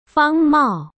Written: Fang Mao

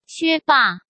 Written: Xue Ba

